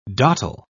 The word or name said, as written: dottle